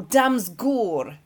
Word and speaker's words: Damsgaard: Mikkel Damsgaard, or Damsgaard, if you're pronouncing it the Danish way.